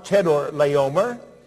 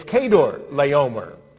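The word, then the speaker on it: Chedorlaomer: It's not Chedor Laomer. It's Kedor Laomer.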